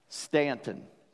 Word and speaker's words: Staunton: Now, if you look for that on a map or Google it, you won't find it, because it's spelled S-T-A-U-N-T-O-N. It spells Staunton, but I say Ant, so this is real easy for me. Anton is the end of Stanton, right?